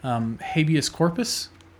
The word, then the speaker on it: habeas corpus: So on the day that he was sentenced to be executed, the federal courts attempted to pend his execution by ways of habeas corpus.